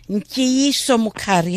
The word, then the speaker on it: Ntiyiso Mukhari: Ntiyiso Mukhari, hello.